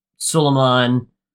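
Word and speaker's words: Suliman: So they go through this whole process of discovering that something is afoot with the Suleiman, who rhymes with Saruman, which is how you know that she's the bad guy.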